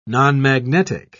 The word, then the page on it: nonmagnetic: British English